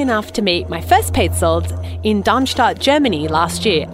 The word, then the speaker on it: Paetzold: So Petzold, it's spelled P-A-E-T-Z-O-L-D.